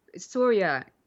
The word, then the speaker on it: Surya: I think it was Surya. It was kind of more like pronounced like Sonya, but it was sorry. It was an R.